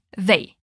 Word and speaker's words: they: They. E cuidado aqui com uma questão de pronúncia. Não é they, mas sim they. Você vai colocar a língua bem gentilmente entre os dentes da frente.